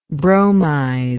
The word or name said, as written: bromizer